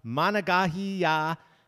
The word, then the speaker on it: Monongahela: It's the Monongahela River pronounced.